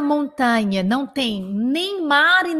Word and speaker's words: montagne: Il n'y a ni la mer, ni la montagne. Não tem nem mar nem montanha. Presta bem atenção no um, no, em como você pronuncia montanha. Este A do tá é forte, ele é montagne montagne, d'accord Montagne.